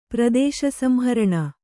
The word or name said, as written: ಪ್ರದೇಶಸಂಹರಣ